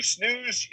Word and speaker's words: snus: This first one is called You Snooze, You Lose. – Okay. – And snooze is spelled S-N-U-S.